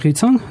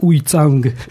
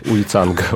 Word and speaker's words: Уйцанг: Центральный Тибет, да, по-тибетски единственное с моим амдосским акцентом называется Хуйцан, да, в лхасском тибетском называется Юцан, но я не умею правильно его произносить, а по-русски его обычно транскрибируют как Уйцанг. – Уйцанг, окей.